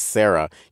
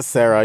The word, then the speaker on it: Sara: And look, because the two American boys were so American when we gave you a shout out the last time, we were mispronouncing your name as Sarah. You're not a Sarah, you're a Sara. I was like, oh my God, I can't believe I'm pronouncing your name incorrectly. Everyone, her name is Sara Caputo.